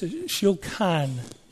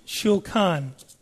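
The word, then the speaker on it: shulkan (שולחן): But the word table stems from the word, I'll say it properly, it's shulkan, shulkan.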